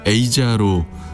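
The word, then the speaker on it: Aja: 노래 안에서는 에이자로 발음하는데 멤버 도널드 페이건의 지인이었던 한국인 여성 에자의 이름에서 따왔다는 뒷얘기도 있습니다.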